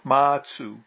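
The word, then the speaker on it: Mazu: Baso is the Chinese way of, no, Baso is the Japanese way of pronouncing the characters, which in Chinese are Matsu, which literally means ancestor Ma or ancestor Ba. With Baso, Ma or Ba means horse.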